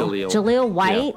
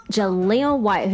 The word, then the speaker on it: Jaleel White: Jaleel White. – Yeah. Jaleel White, who played the super nerd staple of the show, Steve Urkel.